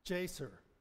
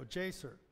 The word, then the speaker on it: Jasher: Pronounce the word, and this is, I looked this up, and it's Jasher. There we go, Jasher. And so Jasher means upright or just.